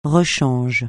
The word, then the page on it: rechange: francais